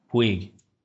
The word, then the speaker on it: Puig: Because this Puig, is that how you pronounce it? Puig? – Puig.